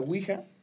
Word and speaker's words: Ouija: Es que mira, si ya entramos a la Ouija... Sí, lo dije bien, ¿no? – La hija, sí. – Sí, la Ouija.